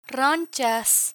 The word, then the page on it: ronchas: spanish